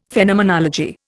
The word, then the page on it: Phenomenology: Indian